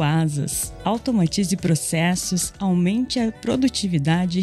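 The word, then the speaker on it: Asaas: Lembrando que Asas se escreve A-S-A-A-S e conheça a conta digital completa para empresas.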